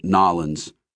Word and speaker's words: New Orleans: "You sound like you're from New Orleans," Debbie told her, doing her best to pronounce it Nollins.